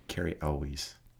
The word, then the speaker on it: Cary Elwes: Cary Elwes.